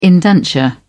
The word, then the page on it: indenture: British English